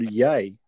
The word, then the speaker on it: Ye: He's now called Ye.